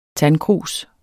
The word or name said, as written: tandkrus